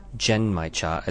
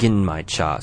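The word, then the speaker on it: Genmaicha: The words Gen Mai Cha actually can be pronounced in a couple of different ways. The Japanese seem to say it as Gen Mai Cha as opposed to the Chinese version, Gin Mai Cha.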